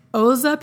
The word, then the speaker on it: Xhosa: Now, side note, this is actually supposed to be pronounced with a click. It's like the Xhosa people, and I can't do it right, so I'm just going to call them Xhosa,